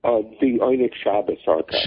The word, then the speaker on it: Oyneg Shabes: Sam, am I pronouncing that correctly? – The Oyneg Shabes Archive. – Shabes Archive. I had the accent wrong.